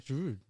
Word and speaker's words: Giroud: Giroud.